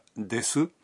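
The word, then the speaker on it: des (です): Recuerden que la vocal U en la sílaba su de la palabra desu es muda, por lo que se pronuncia des.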